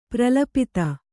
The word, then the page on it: ಪ್ರಲಪಿತ: Kannada